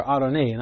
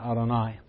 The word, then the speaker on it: Adoni: To this day, in the singular, Adoni, not Adonai, but Adoni, my Lord, this is how you say sir in Hebrew.